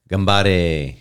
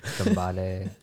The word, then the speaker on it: Gambare: Yeah, but they said it. Gambare. – Yeah. – Like I was like, that's the first thing I realized. I was like, I've been saying it wrong all the time.